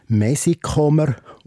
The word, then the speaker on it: Messikommer: Der Familienname Messikomer wird in der Mundart vom Zürich Oberland Witz erwartet als Messikomer ausgesprochen.